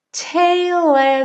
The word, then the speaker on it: tale: Tale as old as time. Tale, a gente não pronuncia a letra E, né? A gente termina no L.